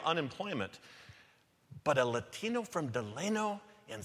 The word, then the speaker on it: Delano: I'm from Delano, California, pronounced Delano, not Delano.